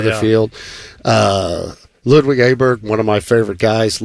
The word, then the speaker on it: Åberg: I didn't realize it's Åberg, but it doesn't look like that.